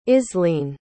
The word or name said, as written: islene